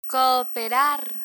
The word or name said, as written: cooperar